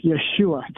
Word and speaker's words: Yeshua: That's not even the Son of God's name. It's Yeshua. Three syllables, not two.